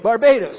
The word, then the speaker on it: Barbados: How do you say that? Barbados or Barbados or something? What is it? – Barbados.